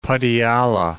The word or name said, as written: Patiala